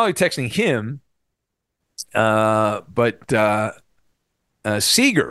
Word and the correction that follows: Sauger: Is it Seeger? Am I saying that right? Am I saying his name right? – Sauger. Yeah. When he first got hired, they're like, think of soccer when you say his name. – Sauger.